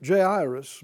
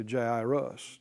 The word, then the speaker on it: Jairus: And of course that sounds similar, doesn't it, to Jairus, Jair. And that's, it's not pronounced with the J's like we say it.